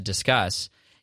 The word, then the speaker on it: Disqus: Discus, which is spelled D-I-S-Q-U-S, or I think it's called Discuss. But I always say it incorrectly. I say Discus, but it's discuss, like you're discussing, like having a conversation, which makes sense, of course.